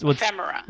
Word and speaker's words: ephemera: She's very into fashion, like vintage kind of, how do you say it? Ephemera? What's ephemera. – All right, thank you.